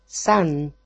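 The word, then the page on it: san: Greek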